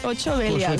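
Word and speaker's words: Vuit Belial: Segons com el pronuncien, al final de les cançons és 8 Belial.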